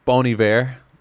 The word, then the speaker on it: Bon Iver: It's Bon Iver.